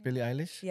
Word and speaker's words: Billie Eilish: Yeah, with Billie Eilish. How do you say her name? – Billie Eilish.